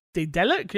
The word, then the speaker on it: Daedalic: Daedalic, yeah.